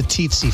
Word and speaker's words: tsetse: No, it's a tsetse fly. – It's not spelled that way. – It's spelled T-S-E-T-S-E. – Tsetse. That doesn't look like tsetse to me. It looks like tsetse in the T.